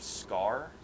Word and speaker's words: Skaar: I mean, in the Planet Hulk series, or the movie, he has two kids with this woman, and one of them's named Scar, but it's spelled like S-K-A-A-R.